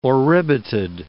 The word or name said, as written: oribatid